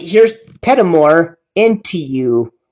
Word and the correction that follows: Paramore: Paramore, Petamore, I can't say the name right. Forgive me, band. Paramore, into you on the show right here on Spotify.